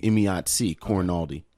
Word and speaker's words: Emayatzy Corinealdi: Emayatzy. – Did I say that right? – Emayatzy Corinealdi.